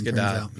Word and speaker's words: Gadot: You pronounce the T, I found out. – I know. – You do? – Yeah. – No, it's not French pronunciation. – It's Gadot. It's Gadot.